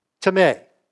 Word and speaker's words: Timē: Now, it looks like time, but it's not. It's a two-syllable word. Timē. That's it. That's how you pronounce it.